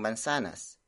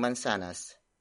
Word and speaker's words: mansanas: The focus of this sentence is ang mansanas. Ang mansanas. Ang mansanas.